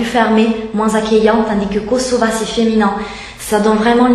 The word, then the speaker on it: Kosova: Alors, Kosova, ils tiennent beaucoup, hein, à cette prononciation, hein, puisque c'est la prononciation en albanaise.